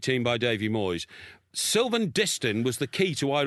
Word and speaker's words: Distin: Sylvain Distin. – Distin. – Distin. – Distin. – Distin. – Distin, yeah. – Distin. – Get his name right.